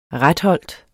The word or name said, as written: retholt